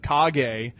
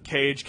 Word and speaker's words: Kage: It's mispronounced Legend of Cage, K A G E.